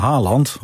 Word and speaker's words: Haaland: Hoe spreek je nou Haaland uit? Ja, wij zeggen Haaland op Radio 1 hier.